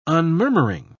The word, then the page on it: unmurmuring: British English